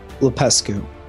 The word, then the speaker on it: Lupescu: LUPESCU.